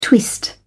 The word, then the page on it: twist: British English